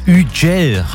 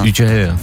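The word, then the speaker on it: Hugel: Ja, Ugel heißt der, wie ich letztens gelernt habe, französischer DJ. Oder wie würdest du ihn aussprechen? – Ja, Ugel, ja. – Ugel, ja.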